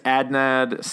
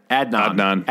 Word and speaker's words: Adnan: One, we mentioned it last week, but on HBO, it's a 4-part series, The Case Against Adnan Syed. – Adnan.